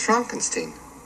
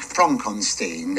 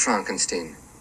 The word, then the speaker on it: Frankenstein: You're putting me on. – No, it's pronounced Frankenstein. – Do you also say Frederick? – No, Frederick. – Well, why isn't it Frederick Frankenstein? – It isn't.